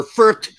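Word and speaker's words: Fürth: Or Firth, if you pronounce it German way.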